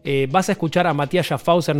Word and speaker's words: Mathias Schaffhäuser: Matías Schaffhauser.